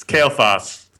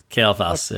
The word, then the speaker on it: Kael'thas: I've been thinking about this since the Alpha, and I think I have to go with, I don't know how to say these, Warrior Muradin, Assassin, Kael'thas. – Well, hold on. He misspelled that character's name. Okay. Yeah. It's Kael'thas. – Kael'thas, yeah.